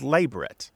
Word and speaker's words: labret: The more strict and traditional pronunciation is labret, with the stress on the first syllable, not the last, and not a Frenchy pronunciation, because it is not a French word.